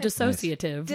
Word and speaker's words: dissociative: Tell me about dissociative. Did I say it right? – Yeah. Or sort of dissociative. I think you might have added a syllable, but I liked it.